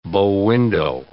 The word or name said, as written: bow window